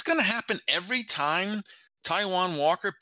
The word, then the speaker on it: Taijuan Walker: Afterward, he said he was worried about Taijuan Walker. Am I saying his name right? – Yeah, that's right.